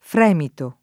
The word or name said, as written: fremito